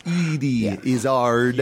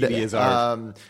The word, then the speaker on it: Eddie Izzard: I believe it's pronounced Edie. – Edie Izzard. – Edie Izzard. – Edie Izzard.